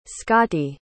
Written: scotty

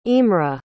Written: imre

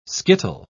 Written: skittle

